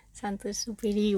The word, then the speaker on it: Saint-Exupéry: But no, I wanted to talk to you about Antoine de Saint-Exupéry, whatever it's called. How would you pronounce it? You're half French. – Exupéry. Saint-Exupéry. Well, I'm not an expert, but yeah, it's just Saint-Exupéry. It's Saint-Exupéry.